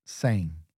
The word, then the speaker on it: sem: And then for without is sang, ang, so it's a nasal sound. Okay. – Okay. – Different letters as well. Sin is S-I-M, sang. S-E-M.